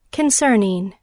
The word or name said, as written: concerning